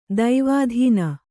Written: ಧವಾದಿನಿ

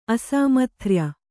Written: ಅಸಾಮಥ್ರ್ಯ

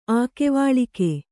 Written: ಆಕೆವಾಳಿಕೆ

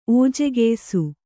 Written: ಓಜೆಗೆಯ್ಸು

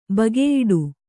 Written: ಬಗೆಯಿಡು

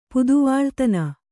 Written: ಪುದುವಾಳ್ತನ

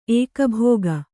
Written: ಏಕಭೋಗ

